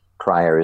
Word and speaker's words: Cryer: Cryer is spelled C-R-Y-E-R.